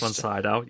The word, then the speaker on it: Max von Sydow: So then there's a shot at 3:30 where it is, if I'm saying this right, Max von Sydow, who was the priest. – Max von Sydow, yeah.